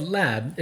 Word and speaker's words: larb: Is it just called Lab? It's pronounced like that, but it looks like L-A-R-B.